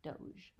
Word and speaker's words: Doge: One-time Venetian VIP was a Doge, D-O-G-E. – Doge. – Doge, is that how it's pronounced? – Yes.